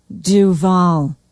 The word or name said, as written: duvall